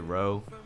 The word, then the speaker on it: Reaux: I'm DJ Roe. That's spelled R-E-A-U-X.